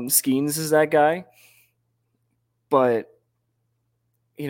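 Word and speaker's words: Skenes: I'm just so happy you said Skeens correctly. – I've been saying it right for weeks, man.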